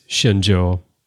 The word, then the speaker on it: Shenzhou: It is Chinese, and I was close. It's the ZH is not zhe, but zhe, but it is Shenzhou.